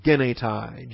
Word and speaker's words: genetai: Hina, H-I-N-A. Aphobos, A-P-H-O-B-O-S. You got the short O, Omicron, the long O, Omega. Hina, aphobos, genetai, G-E-N-E-T-A-I, genetai. This is the purpose clause for the imperative.